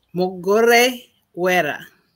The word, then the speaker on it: Mugorewera: Mugorewera.